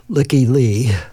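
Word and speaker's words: Lykke Li: And I also like the first song, and I'm not sure how she pronounces her name, but I'm going to pronounce it Licky Lee, L-Y-K-K-E.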